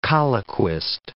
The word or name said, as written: colloquist